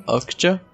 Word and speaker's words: Okja: Okja? – É. – Okja.